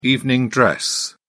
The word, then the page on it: evening dress: British English